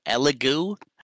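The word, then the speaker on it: Elegoo: I don't know if I'm pronouncing that right. It's E-L-E-G-O-O. Elego.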